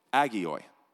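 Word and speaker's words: agioi (ἅγιοι): Saints being a translation of the Greek word hagioi, agioi. H is silent.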